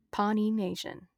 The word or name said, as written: Pawnee Nation